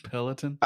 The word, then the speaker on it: Peloton: Peloton. – I like that. I like that. It sounds like Pelican.